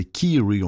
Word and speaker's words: Kyrie: Tout à l'heure, j'ai dit Kyrie. Non, non, c'est Kiri, on entend bien. Il le prononce comme ça, hein, Kiri. K-Y-R-I-E, Kiri.